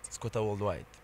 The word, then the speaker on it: Skgothaworldwide: You mentioned that part of SK, how do you say it? – Skgothaworldwide. – Skgothaworldwide.